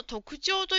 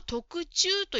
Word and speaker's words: 特徴: 5 番の質問の特徴という言葉ですが、ジムさんは特注と言っていました。ちょっと違いましたね。